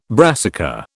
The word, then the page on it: brassica: British English